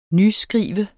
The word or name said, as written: nyskrive